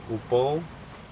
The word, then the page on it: upou: Aneityum